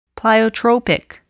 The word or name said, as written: pleiotropic